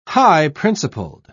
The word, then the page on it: high-principled: British English